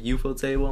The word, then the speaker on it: ufotable: I don't want to butcher it because I have two different ways of saying it. UFO table. – Yeah. The UFO table. – Or ufotable. – Ufotable. That's what I always say.